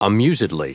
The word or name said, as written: amusedly